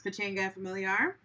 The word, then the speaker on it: Pachanga Familiar: I believe it's pronounced Pachanga Familiar. Is that how you pronounce it? – Yes.